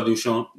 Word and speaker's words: Duchamp: I think the gentleman, I might be pronouncing it wrong, Marcel Duchamp? – Marcel Duchamp. – Duchamp. There you go. Yeah. This is French.